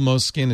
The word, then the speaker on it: Moleskine: It's spelled Moleskine. It's pronounced Moleskine.